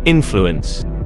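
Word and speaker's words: INFLUEN5: The band's name, now you're gonna want to remember this, it is Influence. But listen up. That's how it's pronounced.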